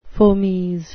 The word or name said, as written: fomes